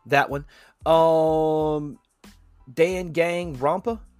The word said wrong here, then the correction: Danganronpa: Danganronpa, which is actually a really good one.